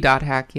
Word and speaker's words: .hack: It is .hack, right? That's how it's pronounced? That's how the kids say it these days? – It should be .hack, yeah.